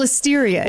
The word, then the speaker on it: Listeria: They'd already dealt with the Listeria crisis in 2015. – I'm sorry, the what crisis? – Am I saying that wrong? It's yeah. Listeria, isn't Listeria. – It? Listeria. Listeria.